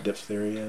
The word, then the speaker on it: diphtheria: How do you pronounce it? I pronounced it one way. What is it? – Diphtheria.